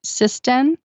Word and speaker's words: Cissden: Is made by, I'm going to say it's pronounced Sisden, C-I-S-S-D-E-N.